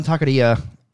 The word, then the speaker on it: taqueria: I've only been to one taqueria, and I think it was up here. – Taqueria. – Roll your R's.